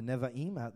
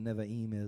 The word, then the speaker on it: Nevi'im: And maybe I got that right, the Nevi'im. I was told last week there are three syllables in it.